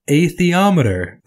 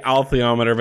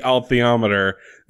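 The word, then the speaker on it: alethiometer: And it's interesting, like, basically, Professor Maloney has built a high-tech, please God, atheometer. Did I get it? – I mean, there's an L in there. – God damn. – I say altheometer, but to be fair, perhaps it's a silent L.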